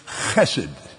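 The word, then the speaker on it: Chesed (חסד): That word, properly pronounced, is Chesed. Oh,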